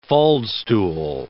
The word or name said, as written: faldstool